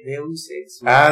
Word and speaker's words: Deus Ex Machina: No, Deus Ex Machina.